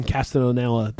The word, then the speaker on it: Castellaneta: They will have enough words spoken by the cast of The Simpsons that they can have Homer say things long after Dan Castellaneta, that's how you pronounce his name, right? – I believe so, yeah.